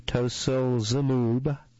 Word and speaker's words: tocilizumab: Based on promising clinical trial results, the Food and Drug Administration approved tocilizumab, the first therapy that specifically targets giant cell arteritis. Let me spell that. T-O-C-I-L-I-Z-U-M-A-B.